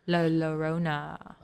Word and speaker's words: Llorona: Llorona? Double L? – The double L does make a Y sound, but I never hear it pronounced like that. – La Llorona.